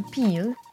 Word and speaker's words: peel: Si on veut parler de la pilule, on utilise le mot pill, P-I-2-L, prononcé avec le i court. Et si on utilise le i long, alors c'est le verbe éplucher, to peel, P-2-E-L.